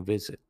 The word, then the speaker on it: vis: There is um, one third declension istem noun worth talking about and taking a visit. It's vis vis.